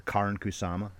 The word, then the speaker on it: Karin Kusama: Because I was impressed with this low-budget film, a new director, Karin Kusama. That is how you pronounce her name.